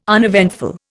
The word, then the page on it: uneventful: Indian